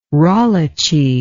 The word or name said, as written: rolliche